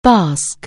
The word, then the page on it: basque: anglais